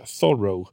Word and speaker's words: Thoreau: Jag hade ju som eh, Toro, han, eh, förlåt, eh, Thoreau. – Mm. Jag försöker vänja mig att han var amerikan och inte ja. fransman, så jag, det är uttalet där.